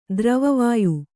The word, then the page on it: ತಿರುವುವೊಯ್: Kannada; tiruvuvoy